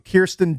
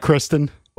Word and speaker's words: Kirsten: Well, then she's saying people mispronounce her name. – They call her Kristen. – Or they call her Kirsten. It's Kirsten. Kirsten Dunst.